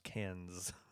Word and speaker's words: Cannes: Cannes. It's pronounced Cannes.